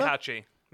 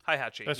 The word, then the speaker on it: Heihachi: How do you pronounce that name? Mishima? – Heihachi. Mishima. – Heihachi? – Heihachi. Yeah.